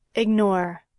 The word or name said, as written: ignore